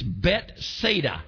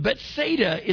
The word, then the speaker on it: Bethsaida: I said it's Bethsaida, house of. Anytime you see the word Beth in there, it really is pronounced Bet.